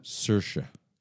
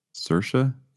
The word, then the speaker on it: Saoirse: Saoirse. – Saoirse? – Yeah.